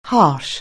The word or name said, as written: harsh